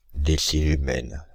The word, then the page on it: décilumen: [de.si.ly.mɛn]; /de.si.ly.mɛn/